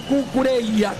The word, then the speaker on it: Cucurella: Por la izquierda, ya hablamos un poco de él, va a jugar Cucurella o Cucurella, como ustedes quieran, pronunciado en español, ¿no? Nosotros tenemos la tentación de hacerlo en italiano y decir Cucurella, pero los españoles le dicen Cucurella, Cucurella.